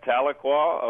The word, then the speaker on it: Tahlequah: With us from, this is a pronouncer, Tahlequah, is that right? – Well, that's it, Tahlequah, Oklahoma.